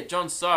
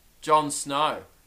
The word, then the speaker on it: Jon So: Oh, did I say it wrong again? Yeah, Jon So. And the whole time I was saying Jon Snow.